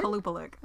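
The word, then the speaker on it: Qalupalik: It wasn't the Kupalik of Alaska. Did I say that right? – No. – Oh. Yes, I did. – Kalupalik. – Oh, Kalupalik. Oops.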